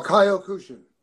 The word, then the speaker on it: Kyokushin: I'll try it. Yokushin. – Oh, Kyokushin.